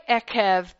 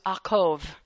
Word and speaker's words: Ekev: First of all, we say Ekev, but it is not Ekev in its true pronunciation. It is Akov. It's actually part of Jacob's name.